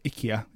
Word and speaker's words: IKEA: And actually, think about last night, there's a new advert, IKEA or IKEA, if you say it properly, I think, have got out. – How you say it? – That's how the advert says it.